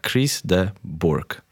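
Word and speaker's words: Chris de Burgh: Chris de Burg.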